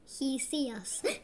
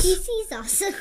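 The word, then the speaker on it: Ctesias: His name sounds like it's He sees us. – Yes. – He sees us. – And it's spelled really weird with a C. C-T-E-S-I-A-S. – And it's pronounced T-C-U-S. – That's right. – T-C-U-S.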